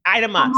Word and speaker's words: Idema: No, Idema.